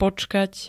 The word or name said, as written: počkať